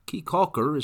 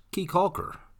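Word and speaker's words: Caye Caulker: Keekalker. Keekalker is pronounced Keekalker.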